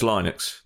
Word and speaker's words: Linux: No, it's Linux. The bloke who made it's called Linus.